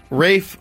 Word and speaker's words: Ralph: How about Rafe Fiennes, whose name is spelled Ralph? My name is spelled Ralph, but I want you to call me Rafe. – The L is silent. – Rafe. – And the A is long.